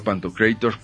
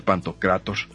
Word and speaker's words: Pantocrator: Suena mejor Pantocrator, pero pues creo que no es esa la pronunciación correcta. Debe ser Pantocrator.